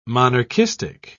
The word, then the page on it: monarchistic: British English